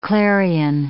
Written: clarion

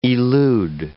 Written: elude

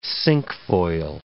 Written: cinquefoil

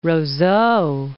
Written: Roseau

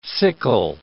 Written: sickle

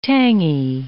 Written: tangy

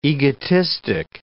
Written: egotistic

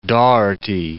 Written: Doherty

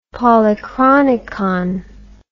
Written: polychronicon

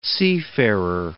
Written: seafarer

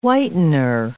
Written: whitener

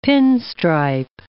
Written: pinstripe

